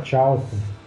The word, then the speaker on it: Charlton: É Carlton Marvel, né? Carlton era a antiga editora lá do Capitão Átomo, do Besouro Azul. – Não era Charlton. – É, sim, uma pronúncia Carlton.